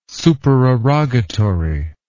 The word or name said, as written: supererogatory